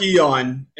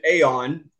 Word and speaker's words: Aeon: You know, the book Dark Eon, and just so you know, as long as we're talking about mispronunciations, I'm going with Eon for two reasons. One is the proper Cambridge pronunciation, although most of the Gnostics whom I know personally pronounce it Aeon.